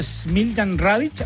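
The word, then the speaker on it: Smiljan Radić: El destacado arquitecto chileno Smiljan Radic,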